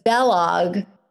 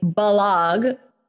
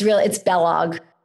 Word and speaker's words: Balog: It's supposed to rhyme with Kellogg. So it's Balog, but it's B-A-L, it's Hungarian. So sometimes I'll say Balog so people get it, but it's Balog, rhymes with Kellogg.